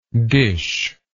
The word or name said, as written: dishlike